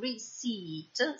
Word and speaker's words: receipt: Con questa I pronunciata lunga la trovate scritta con una E e una I. La pronunciamo receipt senza pronunciare la P. Si tratta dello scontrino. Quindi, come in aisle troviamo scritta questa S ma non viene pronunciata, in receipt troviamo una P che viene anch'essa omessa nella pronuncia.